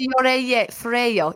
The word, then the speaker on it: Fiorello: You attended, is it the Fiorello? – Fiorello. I didn't say it right. You know, it's Fiorello.